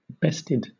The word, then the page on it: bested: British English